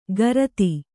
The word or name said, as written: ಕಾರ್ತಿ